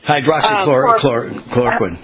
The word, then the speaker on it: hydroxychloroquine: Hydroxychloroquine, yes.